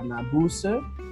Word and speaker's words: Mabuse: Según en alemán, todas las sílabas o todas las este, vocales se pronuncian, entonces sería Mabuse pero bueno, es el doctor Mabus del fabuloso Fritz Lang, del cual vamos a estar hablando bastante el día de hoy.